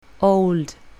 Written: old

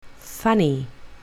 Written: funny

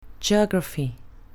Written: geography

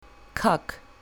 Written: cock

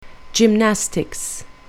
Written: gymnastics

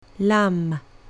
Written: lamb

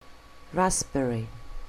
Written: raspberry

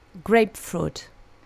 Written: grapefruit

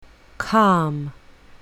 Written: calm

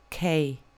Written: k

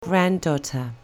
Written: granddaughter

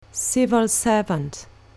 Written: civil servant

